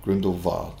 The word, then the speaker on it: Grindelwald: Young Dumbledore puts a very noticeable German-Dutch spin on it, the Wald part pronounced much like the English word vast, Grindelwald, whereas most other characters use a more conventional English pronunciation.